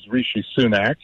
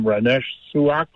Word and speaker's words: Rishi Sunak: One is Rishi Sunak, whose name President Biden doesn't seem able to pronounce. He calls him Ramesh Suak or something.